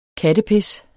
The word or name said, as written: kattepis